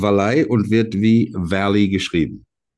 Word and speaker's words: Valley: Wir sind in Oberbayern, Wallei. Wallei? Ein äh, Gmoor, eine äh, Gemeinde in der Nähe von Holzkirchen. Und deswegen wird das Wallei ausgesprochen.